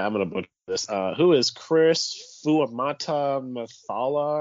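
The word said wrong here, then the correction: Fuamatu-Ma'afala: who is Chris Fuamatu Amaafala?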